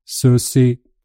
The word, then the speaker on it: sushi: sen efter den här resan var ju att jag att jag, gick in på Google Translate för att höra hur det egentligen uttalas på japanska. Okej. Okay. Och då uttalas det sushi. – Det är inget sje ljud eller tjej-ljud.